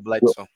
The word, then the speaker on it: Bledsoe: Bledsoe, Bledsoe.